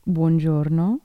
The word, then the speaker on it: buongiorno: Buongiorno. It's like DiGiorno, but with a bon before it. DiGiorno the pizza.